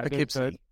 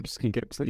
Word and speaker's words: Poughkeepsie: Poughkeepsie is the name of that town. – Poughkeepsie.